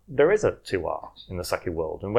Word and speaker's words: terroir: I'm going to have to teach you first how to pronounce it. – Okay. – The word is terroir.